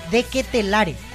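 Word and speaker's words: De Ketelaere: De Ketelare, al final se pronuncia la E.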